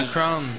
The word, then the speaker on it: KRWN: KRWN, je sais même pas si ça se prononce comme ça. – Ah, le Crown. – Le Crown, ben oui, excusez.